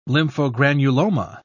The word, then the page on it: lymphogranuloma: British English